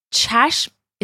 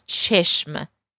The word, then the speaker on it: چشم: چشم is the word for eye. چشم. And just note that in conversation, this often gets pronounced as چشم.